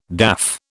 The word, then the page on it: daff: British English